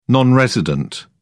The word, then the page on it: non resident: British English